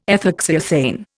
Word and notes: ethoxyethane: Indian

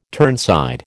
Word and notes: turnside: American English

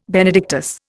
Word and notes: Benedictus: Indian

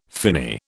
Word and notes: finny: British English

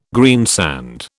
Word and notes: greensand: British English

